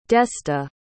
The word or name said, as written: desta